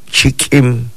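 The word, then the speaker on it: chik'in: Oeste, nosotros lo pronunciamos de esta manera. Chikim, chikim, es oeste.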